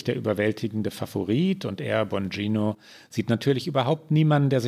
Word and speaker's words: Bongino: In dem Ausschnitt fragt, wie spricht man ihn aus? Bongino? – Ja, so habe ich es gehört. So stellt er sich selbst vor.